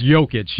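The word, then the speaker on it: Jokić: Yeah, it's Jokic. – Jokic. – Yeah, there we go. It's Jokic, spelled like, yeah. Jokic, but yeah. It's pronounced with a Y.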